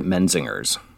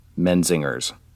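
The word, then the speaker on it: Menzingers: I've always heard it Menzinger's. So not putting the emphasis on the g or j. – No, it's like men and zingers. Menzinger's is how I've always heard it.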